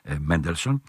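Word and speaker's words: Mendelssohn: όπως προφέρεται από τους Σάξονες, Μέντελσον.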